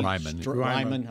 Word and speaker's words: Raimann: Raimann, right? I think he's Raimann. – I said I looked it up and I still said it wrong.